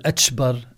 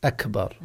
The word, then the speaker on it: أكبر: فمثل هذا أيضا لا يصح، لأنها كاف أكبر، فلا بد أن يتلفظ بها، لا يقول أتشبر أتشبر، ولا يقول واتشبر، ولا يقول أكبر، لا بد أن يحرص على أن ينطق بها بالشكل الصحيح أكبر.